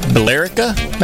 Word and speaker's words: Billerica: It's Billerica? Bill? What? How do you pronounce that? There's an E in there. – Billerica. Billerica, maybe? – Billerica. – Oh, that makes sense. Just leave out the E.